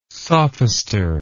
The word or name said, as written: sophister